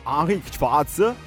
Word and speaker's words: Henrik Schwarz: Sì, dalla pronuncia teutonica Henrik Schwarz.